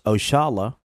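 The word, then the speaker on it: Oxalá: Oxala, pronounced Oshala, a commonly used term, is a direct descendant of a term we Muslims commonly use, Insha'Allah, means God willing.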